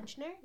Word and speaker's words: Buchner: So, Christopher Bunchner, I think I said that right, B-U-C-H-N-E-R, yeah. – Bunchner, yeah.